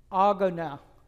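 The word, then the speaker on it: agona (ἀγῶνα): And the Greek word here in this verse that is translated race is spelled A-G-O-N-A, agona, agona. If I say it a few times, maybe you'll kind of get the English translation, agony, okay?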